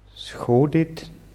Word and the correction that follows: сходить: Ja, also du hast das Verb richtig gewählt hier, Schadit, nur die Betonung ist auf i. Их родители хотят сходить на выставку.